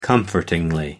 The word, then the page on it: comfortingly: American English